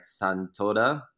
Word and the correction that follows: Satonda: Island? That's where you took a photo. Is that right? – Satonda, yeah.